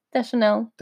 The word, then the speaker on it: Deschanel: Zoe Deschanel. Did I say that right? – Deschanel. – Deschanel. – Is it Deschanel? – Deschanel. Yeah.